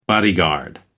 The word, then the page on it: bodyguard: American English